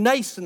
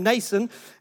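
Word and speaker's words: Mnason: Took about 2 days, and they went up to Jerusalem, and they stayed actually with a man named Nason. That's a silent M on the beginning. It's a man named Nason, and he was a Hellenistic Jew originally from Cyprus, but he had a home in Jerusalem.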